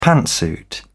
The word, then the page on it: pantsuit: British English